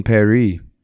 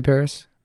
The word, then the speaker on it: Paris: Oh, Emily in Paris. – Is that what is that what they're calling it? – Well, it rhymes when you say it in the French way.